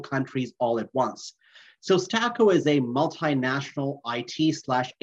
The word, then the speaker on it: Staqo: So big announcement out from the company talking about a partnership with a company called Stako, and it's spelled S-T-A-Q-O.